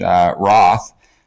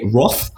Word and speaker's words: Roth: The Germans would say Rot. The Americans and English ones would say Roth. The Portuguese, the Brazilians would say Och.